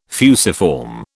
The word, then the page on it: fusiform: British English